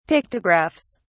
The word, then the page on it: pictographs: British English